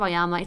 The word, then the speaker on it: Vyayama: But yeah, I mean, definitely check out Vaiama.